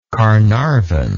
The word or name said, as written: carnarvon